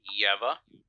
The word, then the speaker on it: Yeva: I'm doing great, and I'm going to go on with my common trope and say that it's pronounced Yeva.